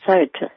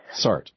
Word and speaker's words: Sartre: Sartre. – Sartre.